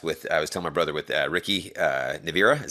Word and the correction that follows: Nierva: Is that how you pronounce his last name? – Yeah, Ricky Nierva. Yeah. – Nierva.